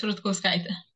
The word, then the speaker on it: Rutkauskaitė: It's Rutkauskaitė.